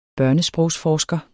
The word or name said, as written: børnesprogsforsker